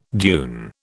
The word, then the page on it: dune: British English